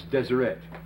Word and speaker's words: Deseret: The word holy here is written very phonetically, Desireo, it's Deseret.